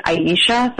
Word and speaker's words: Ayesha: Yeah, and it's it's spelled like A Y E S H A, so most people will pronounce it Aisha.